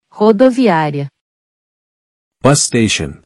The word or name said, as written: Rodoviaria